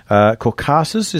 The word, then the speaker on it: Caucasus: Caucasus, is that how you pronounce it? – Oh, yes.